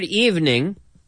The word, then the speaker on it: evening: Now, the word evening, la tarde, evening, it's a two-syllable word. We don't say evening. No, it's evening.